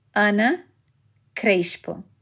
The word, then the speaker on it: Ana Crespo: My name is pronounced Ana Crespo. I speak with a Rio accent, so the S before a consonant is pronounced like sh.